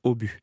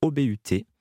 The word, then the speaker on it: Obut: Et j'ai une anecdote là-dessus, puisque à un moment donné, la prononciation de la marque La Boule au but, c'était plutôt au but. Il n'y avait pas la prononciation du T. Et c'est à Europe 1, avec Pierre Belmar, lors d'une annonce publicitaire qu'il a nommé la boule au but, OBUT.